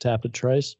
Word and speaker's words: Tapit Trice: And his dam, Dan Satrice, I'm assuming it's Dan Satrice because his name is pronounced Tapit Trice. At least according to John Imbriel.